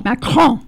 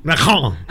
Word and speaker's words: Macron: Yeah, get that R in there. – Macron. – Macron. – Macron. Just clear your throat.